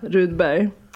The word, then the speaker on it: Rudberg: Omar Rudberg? Rydberg. Ja, Rudberg. Han bara okej okay, Omar, då sa han det typ rätt och sen bara Umar Aha.